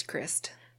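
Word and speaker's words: Krist: It's Christ.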